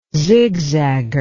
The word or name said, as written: zigzagger